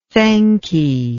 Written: thankee

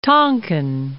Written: Tonkin

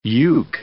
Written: uke

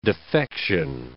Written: defection

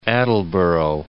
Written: North Attleboro

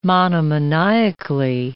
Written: monomaniacally

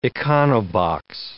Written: econobox

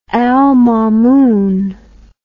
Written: mamunal

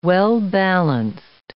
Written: well-balanced

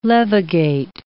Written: levigate

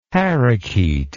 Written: parrakeet

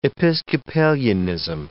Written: Episcopalianism

